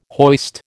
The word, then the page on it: hoist: American English